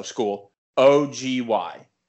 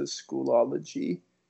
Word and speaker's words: Schoology: We use a website called, it's spelled S-C-H-O-O-L, so school, O-G-Y. How do you say that? – Schoology. – I've been saying it, I think, since day one, Schoology. I add an extra O-L in there.